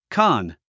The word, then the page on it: con: kάn; コン